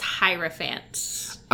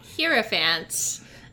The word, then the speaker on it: hierophant: It's actually pronounced hierophant. – Oh. – Not hierophant, as I was pronouncing. I studied German for several years, and whenever you see an E after an I, that's like an E sound, and I just like apply that to like everything.